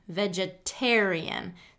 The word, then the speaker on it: vegetarian: And those are that if the T begins a stressed syllable in the word, then it's going to get a full T pronunciation. For example, vegetarian, vegetarian. So tarian is a stressed syllable, so it's going to get that T sound.